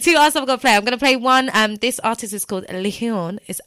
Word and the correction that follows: Lheon: It's actually Leon. I don't know why I said it like that. It's spelled L-H-E-O-N.